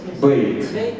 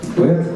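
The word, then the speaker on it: Бэт (בֵּית): Но здесь тоже наше произношение отличается от современного израильского, где в таких случаях произносят Эй, дифтонг. Мы не будем произносить Эй, дифтонг. В современном иврите там звучит дифтонг «эй». «Бейт».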